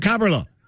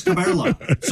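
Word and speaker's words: Kaberle: They went to Cabrilla. I remember that. – Cabrilla. – Cabrilla. – Because we had a recording of him saying, how do you say your name? And he says, Thomas Cabrilla. So we always said Thomas Cabrilla. And people would call it, you're saying his name wrong.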